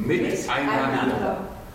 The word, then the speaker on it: miteinander: Und ja, die Betonung in unserem Namen, vielleicht haben Sie es bei unserem kleinen Chor bemerkt, liegt auf miteinander.